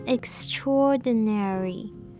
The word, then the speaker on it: extraordinary: 虽然 extra 原本的母音结尾是 r 这样的声音，但是它放在 ordinary 前面的时候， a 碰到 o 就会念成 o 这样的长音，所以这个字会念成 extraordinary。